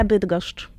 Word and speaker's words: Bydgoszcz: Bueno, se pronuncia Bydgoszcz. – Sí, es una ciudad polaca. – Sí. – Igual la pronuncia de algunas palabras polacas es muy difícil para la gente de aquí, pero sí. Bueno. – Sí, porque tiene, fíjate, al final, por ejemplo, cuatro consonantes seguidas, ¿no?